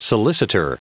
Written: solicitor

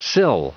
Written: sill

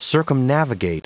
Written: circumnavigate

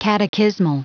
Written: catechismal

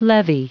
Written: levy

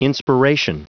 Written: inspiration

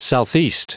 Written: southeast

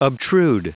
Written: obtrude

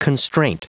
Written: constraint

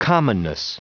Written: commonness

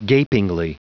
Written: gapingly

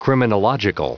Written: criminological

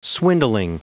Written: swindling

